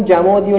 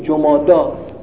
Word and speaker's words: جمادی: مثلا میگن جمادی الاول، بابا نه جمادی نه الاوله، جماده. حالا دیدم جدیدن درست میگن جمادا، عرب میشناسید دیشتیم جمادی چه اسمی؟